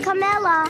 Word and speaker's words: Kamala: It's not Kamala. – It's Kamala.